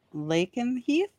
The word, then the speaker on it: Lakenheath: And it was at RAF, I'm going to say this wrong, Lakenheath? – Yep, you got it.